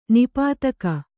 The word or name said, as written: ನಿಪಾತಕ